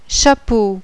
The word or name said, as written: chapeau